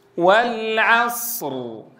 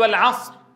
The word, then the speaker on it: والعصر: تنبهوا جيدا عند تلاوة سورة العصر لضبط الأزمنة، لا أقول والعصر، راحت الصاد وراحت الراء. الصاد حرف رخو فزمنه يكون أطول ما يكون، الراء بيني فيكون زمنه متوسطا، هكذا أنطقها. والعصر، هكذا سمعت الصاد وسمعت الراء، ولا أقول والعصر، لا سمعنا الصاد ولا سمعنا الراء. تعطي الصاد حقها من الزمن ثم تنطق راء ساكنة مع حقها من البينية.